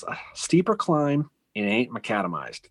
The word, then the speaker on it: macadamized: Am I saying that right? Yep, macadamized. So, yep.